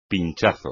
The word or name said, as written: pinchazo